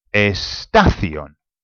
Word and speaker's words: estación: Now, so if you see a word and it's got an accent on it, then you know that you're going to have to put the emphasis on that word. On that vowel. So, for example, if we think of, I don't know, station. How do you say station? – Estación. – Okay, estación. Now, it finishes with an N. It's the nose rule. So it actually should be, truly, it should be estación.